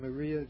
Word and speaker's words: Marija: Marija with a J. Maria Gimbutas.